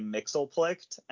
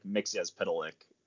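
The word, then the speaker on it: Mxyzptlk: I've heard a lot of fans say Mixelplicht, and to me, that's just easier to say. But no, you're right. Like, the correct way is like Mixyazpitalik or something like that.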